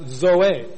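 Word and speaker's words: zoe (ζωή): There's another word pronounced zoe, spelled Z-O-E.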